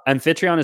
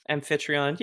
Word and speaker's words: Amphitryon: Amphitryon is how I would say it, or Amphitryon. – Amphitryon. Yeah, exactly. – Yeah. Amphitryon.